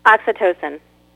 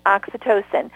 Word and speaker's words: oxytocin: Oxytocin. – Oxytocin.